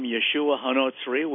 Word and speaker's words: Yeshua HaNotzri: They call him Yeshua HaNotzri, which is exactly correct. That's the way his name should be pronounced and spelled, Joshua of Nazareth.